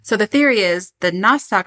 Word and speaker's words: Nostoc: Another scientist said the meat shower was actually a N-O-S-T-O-C, Nostoc? – Nostoc.